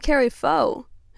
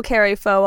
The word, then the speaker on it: Kari Faux: That's N-U-M-I. Next up, we're going to listen to Carrie Foe, who is famous. I recognize this. You probably know Carrie Foe already. It's spelled K-A-R-I-F-A-U-X. She is a pop princess.